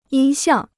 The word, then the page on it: 音像: yīn xiàng